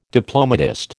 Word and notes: diplomatist: American English

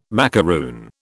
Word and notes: macaroon: British English

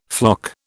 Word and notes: floc: British English

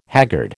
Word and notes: haggard: American English

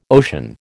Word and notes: ocean: American English

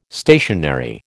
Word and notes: stationary: American English

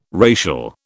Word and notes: racial: British English

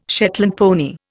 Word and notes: Shetland pony: Indian

